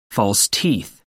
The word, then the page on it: false teeth: American English